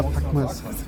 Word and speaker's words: Packmas: Wie bringt jetzt den Flo dazu, dass der sich Puckmas an gleicher Stelle tätowieren lässt? – Sag mal, kannst du nicht unseren Namen auch einfach mal richtig aussprechen? Es ist Packmas am Spätel. – Ja, Packmas.